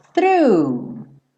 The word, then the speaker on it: through: E o through não precisa ter mistério. Lembrando que para fazer o GH ali do final você pode fingir que não existe, porque você não vai ter que pronunciar.